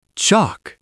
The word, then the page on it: chalk: American English